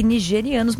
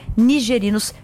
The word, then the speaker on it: nigerinos: E falamos aqui nigerianos, mas na verdade a pronúncia é correta, né? No caso, são cidadãos, né, nigerinos.